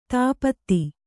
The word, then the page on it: ಧೂಪಿತ: Kannada